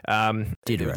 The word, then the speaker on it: Diderot: Yeah, well, hopefully listeners didn't skip the intro because I began a story of the French philosopher Denis Diderot, if that's how you pronounce his name. – Diderot. – The silent T.